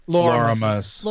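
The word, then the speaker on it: Loramus: I think that with characters like Lorathmus, how do you pronounce his name? – Loramis. – Loramis, thank you. With Loramis, I want to stick a TH in there. I don't know why. I'm blaming Lorthamar.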